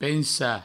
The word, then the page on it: pensar: Provençal; pensa